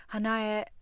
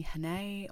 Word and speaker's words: Hanae: So Hanae is my pronunciation correctly in Japanese, but everyone calls me Hanae or Hana.